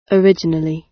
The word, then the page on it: originally: British English